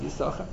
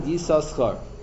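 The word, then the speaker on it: Yisachar (יששכר): Yisachar is pronounced Yisachar. It's not that it's a Kri Aksiv. The correct spelling is 2 shins, even when you're writing a Ksuv in a get. And others say that that sometimes you pronounce it Yisoschar.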